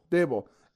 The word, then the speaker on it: Daboll: Daboll.